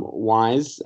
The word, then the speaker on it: Wyze: Um, Wyze, uh, spelled W Y Z E, even though it's pronounced like W I S E is the uh, home camera that you need.